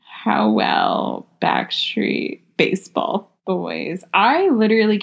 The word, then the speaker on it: Howell: I can barely say Howell.